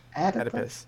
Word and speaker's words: Oedipus: How is it pronounced in English? – Oedipus complex? – Oedipus. Wow.